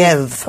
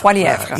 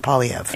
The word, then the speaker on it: Poilievre: Poilievre, I believe. – Poilievre. – Poilievre, yes. – Okay. – But he says he's fine with Poilievre.